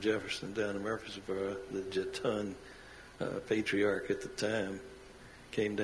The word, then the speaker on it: Jetton: and some of us still struggle with how you say it. Jeton. – Mm-hmm. Jeton. – J-E-T-T-O-N.